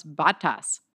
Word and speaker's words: Bottas: So B-O-T-T, Paul always goes, how do you pronounce B-O-T-T? Bottle. I go, yeah, bottle. He's like, yeah, that's how you pronounce Bottas.